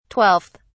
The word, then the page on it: 12th: British English